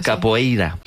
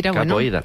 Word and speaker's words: capoeira: Pues todos sabemos lo que es la copeira, ¿verdad? – Capoeira. – Qué mal pronuncio últimamente. – Capoeira.